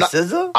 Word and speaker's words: SZA: Her name is SZA? S-Z-A. Like, but that's how she pronounces it.